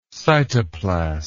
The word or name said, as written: cytoplast